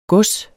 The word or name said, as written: GODSERNES